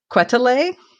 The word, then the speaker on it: Quetelet: His name was Adolphe, and I want to pronounce this correctly. I did look it up, so I hope I'm doing it right. Quetelet. It's spelled Q-U-E-T-E-L-E-T. So it's a little bit rough for my American tongue, but I think that's what it is.